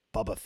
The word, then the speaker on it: Boba: But you know what, like, even Temuera Morrison, he kind of says, like, Boba Fett. It's more like, yes, like a Bubba, like it's different. Yeah.